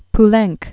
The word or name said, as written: Francis Jean Marcel Poulenc